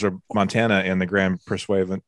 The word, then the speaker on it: Pursuivant: Isn't that pronounced the Grand Pursuivant? – Pursuivant. – Yeah.